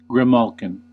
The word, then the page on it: grimalkin: American English; /ɡrɪˈmæl.kɪn/; /ɡrɪˈmɔːl.kɪn/